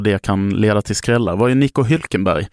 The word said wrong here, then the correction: Hülkenberg: eller Hülkenberg som man ska uttala. Jag såg att du rynkade på näsan där till mitt ursprungliga uttal.